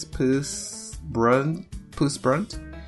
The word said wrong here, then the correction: Persbrandt: and his character's name is Jakob. – Come again, who? – Okay, let's see if I can say that correctly. – Mikkel Persbrandt. – Yes, Mikkel Persbrandt.